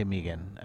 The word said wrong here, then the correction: Mahegan: and I don't pronounce it right, but I'm Mahegan, Jake Mahegan.